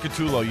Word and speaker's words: Gattullo: Instagram, at Catullo. You spell that G-A-T-T-U-L-L-O.